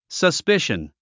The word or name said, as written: suspicion